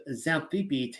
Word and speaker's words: Xanthippe: but then a woman by the name of Xanthippe takes the helm of this passage. The X is pronounced like a Z.